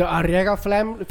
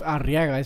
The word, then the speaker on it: Arriaga: And it's not pronounced Arriaga; it's pronounced Arriaga. Like it's a fucking weird. Like the double, you don't hear the double R.